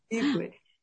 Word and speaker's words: Izcue: ¿Lo dije bien el apellido? ¿Es Iscue? – Perfecto, lo dijiste ah, perfecto. – Sí, Iscue.